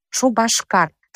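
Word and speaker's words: Шупашкар: Например, чувашское название города Чебоксары – «Шубашкар». Там произносится буква «б», но на письме пишется буква «п».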